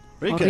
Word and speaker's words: Rilke: ¿Rilke? – Rilke.